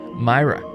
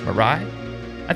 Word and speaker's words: Mirai: Google Toyota Myra. I think I'm saying that right. Mirai? I think it might be Mirai. M-I-R-A-I. Toyota M-I-R-A-I.